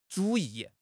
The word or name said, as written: 主义